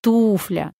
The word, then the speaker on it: туфля: У нас туфля или туфля? Опять любители комедии Леонида Гайдая попали впросак. Норма ударения в слове «туфля» на первый слог.